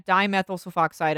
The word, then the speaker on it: dimethyl sulfoxide: But they also said that it was possible that Gloria Ramirez was taking diethyl sulfoxide. What is diethyl sulfoxide, you are asking? No. – Oh, okay. – Dimethyl sulfoxide. I said it wrong the first time.